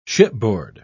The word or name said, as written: shipboard